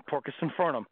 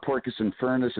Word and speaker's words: Porcus Infernum: Well, thank you for pronouncing it properly, man, because, you know, it's like I get, you know, emails and phone messages like Porcus Infernus and Porcus everything, and I'm like, well, at least they got the Porcus part right.